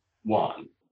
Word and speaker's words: Juan: And then jump ahead a few lines, that phrase, a new one, rhymes with, I'll therefore take our ancient friend Don Juan.